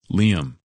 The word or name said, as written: Liam